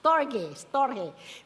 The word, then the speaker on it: storge: There is the storge. How do you pronounce that? Storge. Which is familiar love, love for family.